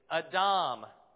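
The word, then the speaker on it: Adam (אדם): Yeah, it's Adam, which sounds like Adam, okay?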